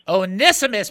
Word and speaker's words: Onesimus: Onesimus? – Onesimus, but that is correct.